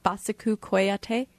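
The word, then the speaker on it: Bassekou Kouyaté: Please correct me if I have the name, if I mispronounce it. Basiku Koyate? – That's pretty close.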